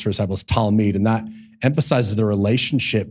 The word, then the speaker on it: Talmid: or one of the Hebrew words for disciple is Talmid. And am I saying that right, Matt? You think so?